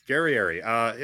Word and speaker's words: Guerrieri: Guerrieri. – Did I get it right? – Yes, close enough. – Okay, Guerrieri.